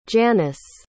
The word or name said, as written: Janice